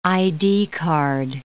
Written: ID card

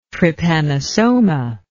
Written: trypanosoma

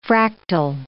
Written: fractal

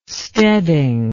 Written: steading

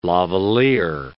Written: lavalliere

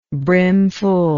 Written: brimfulness

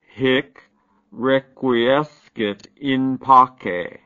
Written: hic requiescit in pace

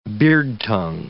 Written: beardtongue

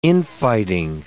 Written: infighting

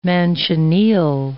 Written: manchineel

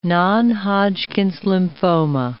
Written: non-Hodgkin's lymphoma

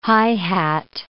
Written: high-hat

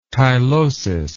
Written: tylosis

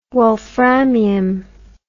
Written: wolframium

